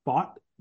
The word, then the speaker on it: Faught: Now, I will say the Diamondbacks pronunciation guide on Brendan Faught apparently has it as Faught, like F-O-U-G-H-T. I guess I trust them, but I'm struggling to get there.